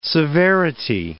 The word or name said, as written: severity